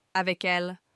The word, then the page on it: avec elle: French